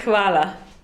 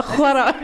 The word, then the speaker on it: hvala: Il faut prononcer le H alors. – On va prononcer le H. – Ça se dit hvala. – Comment? – Hvala. – Hvala. – Oh, presque, c'est très bien. – D'accord, bah, hvala. – H-V-A-L-A.